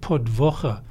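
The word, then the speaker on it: podłoga: And It's pronounced podwocha because it's written as podloga, but it's pronounced as Prodvocha. – In Polish, or yeah. not in Yiddish. – The L in Polish is pronounced as an L by the Jews, but as a W in Polish.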